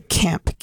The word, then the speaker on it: Kemp: We have the one and only Kemp Key. K-E-M-P. Let me make sure I'm saying that correctly. – Correct, yeah.